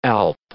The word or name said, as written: alp